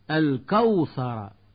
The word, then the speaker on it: الكوثر: الكوثر، ويقول من يخطئ الكوثر بتفخيم الكاف والثاء.